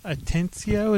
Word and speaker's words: Atencio: Well, that's X Atencio.